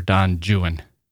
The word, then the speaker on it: Don Juan: Byron later used some of these stories of Foul Weather Jack and some of the rumors about him to populate his poem, Don Juan, or Don Juan, as Byron pronounced it.